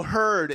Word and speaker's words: Hurd: Is that H-E-R-D? No, H-U-R-D. – What? Oh, heard. It's like turd. – It's turd with an H.